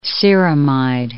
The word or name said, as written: ceramide